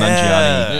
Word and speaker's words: Nanjiani: Kumail Nanjiani.